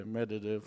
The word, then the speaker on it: Medvedev: Did I say his name right? – Yeah, Medvedev. Good enough. You're good.